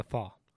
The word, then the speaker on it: pho: Pho. Yes, I think it's P-H-O is how it's spelled. – Isn't that pho? – They pronounce pho. – Pho. – Yeah. Just the way they pronounce it there.